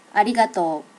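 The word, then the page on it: arigato: native Japanese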